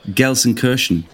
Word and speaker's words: Gelsenkirchen: I'm saying Gelsenkirchen.